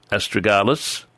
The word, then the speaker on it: Astragalus: If you write down Astra, A-S-T-R-A, Astra. – Yes. – Galus, G-A-L-U-S. Astragalus. – Yes. – And put an 8 beside it. – Yes. – Now, let me explain that, as I've frequently done on this program. Astragalus